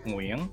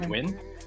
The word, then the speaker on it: Nguyen: Now it's closer to, so the actual pronunciation in Vietnamese is Nguyen. That's very difficult for most people to say, but the key thing to remember here is that it's one syllable, it's monosyllabic, so it's win, like win or lose.